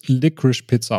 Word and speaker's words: Licorice Pizza: Also laut diversen Interviews wird es Licorice Pizza ausgesprochen.